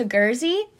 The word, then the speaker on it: Guernsey: So he's exiled to Guernsey, is what I was told to pronounce it as.